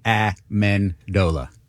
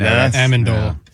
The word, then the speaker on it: Amendola: How do you pronounce Danny's name? – Amendola. – Amendola, I thought. – No, Amendola.